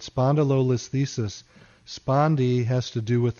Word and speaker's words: spondylolisthesis: And they have, doctors have determined that I have, and I hope I pronounced this correctly, spondylolisthesis. – Yes, you did. Perfect.